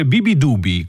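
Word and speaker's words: Beabadoobee: che il cui nome si scrive Beabadubi ma si legge Bibidubi.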